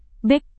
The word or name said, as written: bek